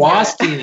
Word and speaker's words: Guastini: Am I pronouncing that last name right, Katie? – Guastini. Nobody can Guastini. – Hear it. – There we go.